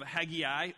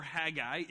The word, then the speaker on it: Haggai: Well, today, as Keith said, we're jumping into the book of Haggai or Haggai. It can be pronounced both ways.